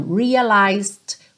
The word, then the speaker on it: realized: E esse também é um padrão dessas palavrinhas terminadas em T-I-O-N in English. I realized quickly when I knew I should. Então, realized Cuidado para não pronunciar o E, não é realized, ok? E você quase não ouve o D, porque ela já está indo para o quickly. Uhum.